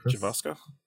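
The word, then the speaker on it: Gavankar: So Janina, how do you say her last name? Gavaska? – Javaska.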